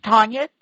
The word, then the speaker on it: Tonya: It's Tonya T O N Y A,